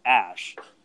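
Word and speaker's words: æ: But the A in the E in there, that symbol that has like that A combined with the E, is actually pronounced Ash.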